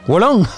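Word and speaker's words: Wo Long: Lo inaugura el Gulong Hu, no sé cómo se dice esto, ayúdame, es Gu Fallen Dynasty, ¿lo he dicho Yo bien? Digo... – Mm, Wolong.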